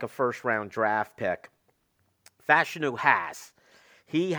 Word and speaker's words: Fashanu: And that's Olu Fashanu, or now it sounds like it's Fashanu is the way they've been pronouncing the name.